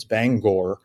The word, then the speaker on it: Bangor: But it's not Bangor, Maine. It's Bangor, Maine. It's kind of pronounced more or less the way you might have thought it was.